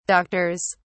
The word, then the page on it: doctors: British English